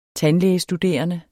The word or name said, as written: tandlægestuderende